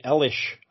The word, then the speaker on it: Eilish: one of the songs is by Billie Eilish. I've heard of that name. – Elish. – And clearly you haven't, because you don't know how to pronounce it.